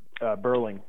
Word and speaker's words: Burling: Is it Berling? Berling. B-U-R-L-I-N-G.